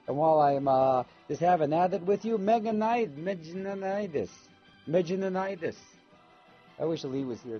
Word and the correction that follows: meningitis: to tell me how do you pronounce that, Lee? Meningitis. Thank you, Lee, baby.